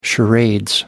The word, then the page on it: charade: American English